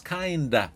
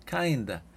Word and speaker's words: kind of: Perdón, que se escribe kind of, pero se pronuncia muchas veces kinda, kinda.